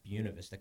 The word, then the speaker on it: Buena Vista: It's pronounced Buena Vista, Colorado, despite the proper English.